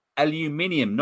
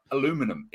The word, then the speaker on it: aluminium: Like a can of beer is made out of aluminium, not aluminum. It's aluminium.